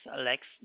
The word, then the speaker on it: Lex: My name is Lex. That is spelled as L-E-X. – L-E-X? – Mm-hmm.